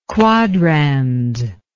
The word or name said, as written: quadrans